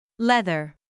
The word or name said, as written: leather